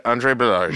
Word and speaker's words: Balazs: Andre Balazs.